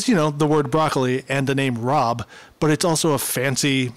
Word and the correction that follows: rabe: Broccoli, R-A-E-B, Broccoli Rob. – That's Rabe. That's pronounced Rabe.